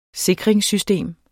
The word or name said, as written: sikringssystem